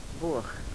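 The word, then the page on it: boh: dialetto croato di Lukezi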